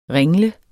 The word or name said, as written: ringle